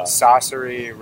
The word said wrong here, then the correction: Saoirse: Ronan is her name. I don't want to mispronounce it. Saoirse. Sorry. I'm sorry.